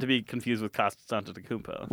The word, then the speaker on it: Antetokounmpo: Giannis Antetokounmpo. – I think you just mispronounced it. – Antetokounmpo.